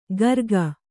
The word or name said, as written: ಗರ್ಗ